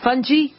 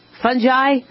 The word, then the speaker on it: fungi: I remember in school, our teachers called it fungi. Fungi? It's not fungi. What are they trying to say, like bonsai? It's fungi on the human body because it's fungus.